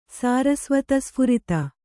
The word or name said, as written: ಸಾರಸ್ವತಸ್ಫುರಿತ